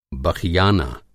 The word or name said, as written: बख़ियाना